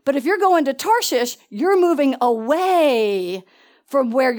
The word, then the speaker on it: Tarshish: Am I saying it right, Tom? Tarshish. Okay.